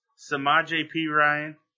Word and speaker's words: Samaje Perine: For me, I got Joe Mixon, Leonard Fournette, Corey Davis, Dalvin Cook, Christian McCaffrey, Kareem Hunt, Mike Williams, Samaje Perine, that's how you say his name, John Ross, and O.J.